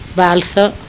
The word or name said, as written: Balsa